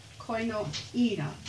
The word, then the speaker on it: koinonia: The base of the Greek word, koinonia, I'm going to do that phonetically, koinonia, is a sharer, a companion, a union by association, and it denotes completeness.